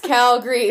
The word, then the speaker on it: Calgary: Everyone knows there's no A at the end. – Yeah. – It's Calgary. – It's two syllables.